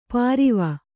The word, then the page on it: ಪಾರಿವ: pāriva